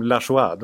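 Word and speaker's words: Lajoie: Om man, om man, om man, om man skriver sitt namn Lajoje och vill ha det uttalat som Lachois, då får man skylla sig själv.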